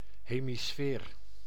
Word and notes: hemisfeer: /ɦeː.miˈsfeːr/

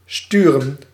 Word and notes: sturen: /ˈstyːrə(n)/; /sty.ɾǝⁿ/

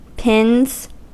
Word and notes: pins: American English

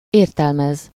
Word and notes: expliquer: France; [ɛks.pli.ke]

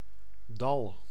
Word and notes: valley: American English

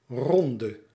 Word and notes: reprise: France; [ʁə.pʁiz]

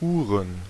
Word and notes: Uhren: /ʔuːɐ̯n/; /ˈʔuːʀən/

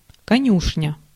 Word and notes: конюшня: /kɐˈnʲʉʂnʲə/; konjušnja

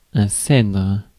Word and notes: cèdre: France; IPA: [sɛdʁ]

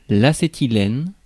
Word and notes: acétylène: France; [l‿a.se.ti.lɛn]; /a.se.ti.lɛn/

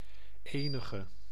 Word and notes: quelque: [kɛlk]; /kɛl.kə/

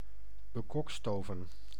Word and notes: bekokstoven: /bə.ˈkɔk.sto.vɛn/

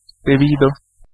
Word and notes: debido: /deˈbi.do/

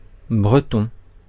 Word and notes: Breton: /bʁə.tɔ̃/

